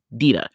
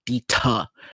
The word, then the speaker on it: Dita: Just for listeners who do not know me, my name is spelled Dita, but it is Hebrew and the T is a soft T. It's a D, Dita. And white women love saying Dita.